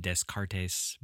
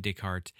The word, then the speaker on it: Descartes: People from my area would probably pronounce it Descartes, but, you know, that's incorrect. He was French, René Descartes, and he is the person who made the very famous philosophical statement, "I think, therefore I am."